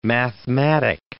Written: mathematic

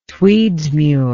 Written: Tweedsmuir

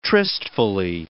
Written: tristfully

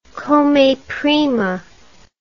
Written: come prima

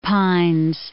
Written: Isle of Pines

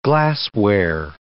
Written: glassware

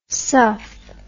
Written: suff

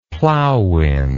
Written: plowwind

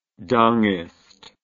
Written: dengist

